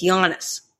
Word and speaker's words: Giannis: It's Giannis.